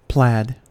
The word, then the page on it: plaid: American English